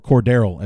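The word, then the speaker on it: Cordarrelle: And I just read an article today about uh, Matt Nagy is, and I quote, a kid in the candy store with Cordell Patterson or Cordero as his name as needs to be pronounced.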